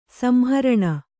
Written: ಸಂಹರಣ

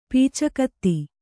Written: ಪೀಚಕತ್ತಿ

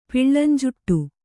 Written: ಪಿಳ್ಳಂಜುಟ್ಟು

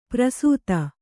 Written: ಪ್ರಸೂತ

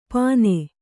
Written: ಪನೆ

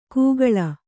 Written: ಕೂಗಳ

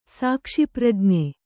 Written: ಸಾಕ್ಷಿಪ್ರಜ್ಞೆ

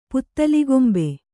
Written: ಪುತ್ತಲಿಗೊಂಬೆ

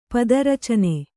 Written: ಪಾದಾರ್ಚನೆ

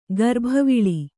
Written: ಗರ್ಭವಿಳಿ

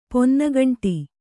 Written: ಪೊನ್ನಗಂಟಿ

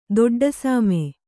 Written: ದೊಡ್ಡಸಾಮೆ

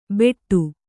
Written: ಬೆಟ್ಟು

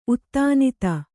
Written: ಉತ್ತಾನಿತ

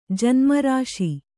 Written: ಋಷಿ

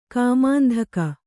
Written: ಕಾಮಾಂತಕ

